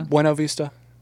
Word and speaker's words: Buena Vista: I butchered that pronunciation, but it was still fun to say. – Buena Vista? – Buena Vista. – You killed it.